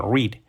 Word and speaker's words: read: Cuidado que read, el participio o el pasado, también es read, se escribe igual que el, infinitivo, read. Se escribe read, read, read, se llama la pronunciación distinta.